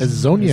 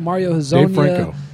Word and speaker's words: Hezonja: Uh, how do you say his last name? – His, Hezonja. Hezonja, Mario Hezonja.